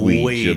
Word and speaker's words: Ouija: Is that an Ouija board, if it's me. – And a Ouija board. – But if it's Zach Bagans, it's a Ouija board. – He throws an extra syllable.